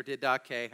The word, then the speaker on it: Didache: So there's a book called the Didache, or Didache, however you want to pronounce it.